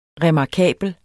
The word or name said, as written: remarkabel